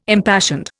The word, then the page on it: impassioned: Indian